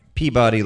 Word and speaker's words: Peabody: Now, it's spelt Peabody, P-E-A-B-O-D-Y. Now, of course, it's not Peabody like in Mr. Peabody, like you'd see in all those old Bullwinkle cartoons. It's Peabody.